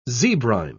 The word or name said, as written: zebrine